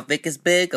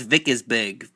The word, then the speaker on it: Vickersberg: Yeah, hey Tim, my name's Sal Vickersberg, I'm calling about the entry-level openings I saw there on the Craigslist.